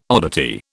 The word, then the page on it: oddity: British English